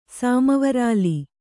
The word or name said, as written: ಸಾಮವರಾಲಿ